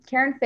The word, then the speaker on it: Caren: Yeah, so I mean, I'm, you already know my name, Caren Fehr.